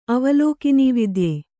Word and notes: ಅವಲೋಕಿನೀವಿದ್ಯೆ: avalōkinividye

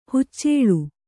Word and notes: ಹಚ್ಚೆಲ: haccela

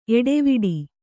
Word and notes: ಏಟವೀಟ: Kannada

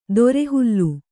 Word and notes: ತೊರೆಹಾಲು: tore hālu

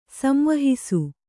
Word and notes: ಸಂವಹಿಸು: kannada

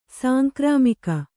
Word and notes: ಸಾಂಕ್ರಾಮಿಕ: Kannada

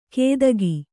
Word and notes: ಕೇದಗಿ: kannada

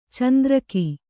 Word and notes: ಚಂದ್ರಕೆ: candrake

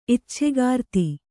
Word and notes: ಇಚ್ಛೆಗಾರ್ತಿ: icchegārti

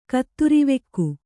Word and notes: ಕತ್ತುರಿವೆಕ್ಕು: kaatturivekku

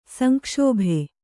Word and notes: ಸಂಕ್ಷೋಭೆ: sankṣōbe

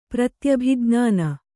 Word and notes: ಪ್ರತ್ಯಭಿಜ್ಞಾನ: pratyabhi jñāna; Kannada

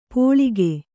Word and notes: ಪೋಳಿಗೆ: Kannada

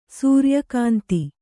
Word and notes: ಸೂರ್ಯಕಾಂತ: sūrya kānta

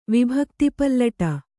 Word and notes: ವಿಭಕ್ತಿಪಲ್ಲಟ: kannada

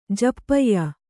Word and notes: ಜಪ್ಯ: Kannada

